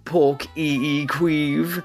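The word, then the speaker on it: Páirc Uí Chaoimh: This daughter person has asked that I bring her to see some chap called Ed Sheeran in a place in Cork pronounced Pork E E Queeve.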